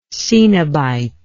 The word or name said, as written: cenobitism